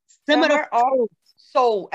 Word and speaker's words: Summer of Soul: Gente, é Summer of Souls, desculpa, falei errado. – Summer of Soul, é, de alma, é.